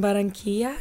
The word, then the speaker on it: Barranquilla: So you were born in Barranquilla, mm-hmm.